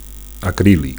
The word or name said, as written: acrilic